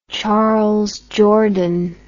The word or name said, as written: Charles Jourdan